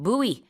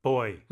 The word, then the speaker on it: buoy: 네, 그 안쪽에서 안전하게 수영을 하셔야 되는데 부표 영어로 buoy. 또 다른 발음이 있어요. buoy. – Boy. – 이건 그래서 헷갈릴 수 있어요. 그냥 남자 아이랑 이제 발음이 같으니까. 근데 boy라고 하기도 하고 buoy라고 하기도 하는데 스펠링은 buoy고요.